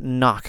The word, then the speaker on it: cnoc: Now, I looked up knock because I figured this is probably coming from the Gaelic word for hill. And I was thinking, I think you pronounced the C. It's spelled in Gaelic, it's spelled C-N-O-C.